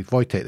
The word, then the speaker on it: Wojtek: And Wojtek the Bear is spelt W-O-J-T-E-K. Wojtek the Bear, so there's a kind of famous story behind that as well. But it is, if you do see it, it's Wojtek. That's the proper pronunciation of it.